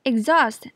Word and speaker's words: exhaust: exhaust 的重音放在第二音节，这个字的发音要特别注意，第一个音节念短音 ig，第二音节的 h 完全不发音，念 zost，au 念 aw，exhaust。